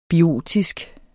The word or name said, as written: BIOTISK